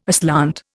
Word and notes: aslant: Indian

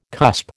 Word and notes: cusp: American English

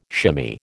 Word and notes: shimmy: American English

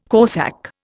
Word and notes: corsak: Indian

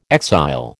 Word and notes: exile: American English

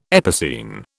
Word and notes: epicene: British English

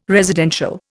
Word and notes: residential: Indian

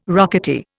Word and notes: rocketeer: Indian

